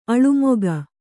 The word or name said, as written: ಅಳುಮುಖ